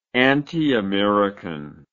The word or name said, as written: antiamericanism